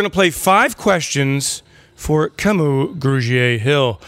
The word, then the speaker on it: Kamu: It's just, that's how it's pronounced. But Kamu. Yeah, it's just a long A. Kamu.